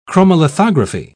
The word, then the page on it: chromolithography: British English